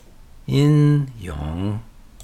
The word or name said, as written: Yin Yang